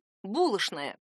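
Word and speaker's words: булочная: «Что?» «Конечно!» «Булошная!» Правда, произношение «шн» больше характерно для москвичей. «Булошная» — это старомосковская норма, а произношение «чн» — для жителей Петербурга.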